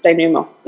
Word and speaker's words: denouement: So I think it's denouement, yeah. – Denouement. – Yeah, it's French.